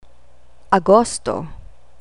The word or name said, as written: agosto